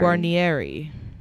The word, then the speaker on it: Guarnieri: Guarnieri. – Guarnieri.